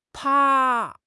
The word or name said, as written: 趴